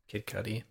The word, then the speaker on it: Kid Cudi: And Kid Cudi, is that how you say his name? Kid Cudi? – Kid Cudi.